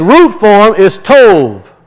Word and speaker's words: tov: It's pronounced with a V. Tov. We see the B and we want to pronounce it in English. But if you see a B with a line underneath it, that's a V sound in Hebrew.